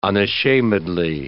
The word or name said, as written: unashamedly